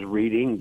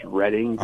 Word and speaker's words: Reading: Reading, Pennsylvania, that sometimes gets mispronounced as Reading, but it's Reading.